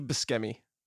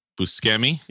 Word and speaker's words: Buscemi: How do you pronounce his name? Steve. – Steve Buscemi. – Buscemi? – Yeah.